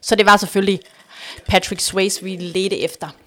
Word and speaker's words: Swayze: Jamen, vi var på udkig efter uh, Patrick Swayze. Patrick, Swayze. Patrick Swayze. Der var lidt uenighed om, hvordan man udtaler efternavnet, Sway, men vi ved Swayze alle, hvem han er.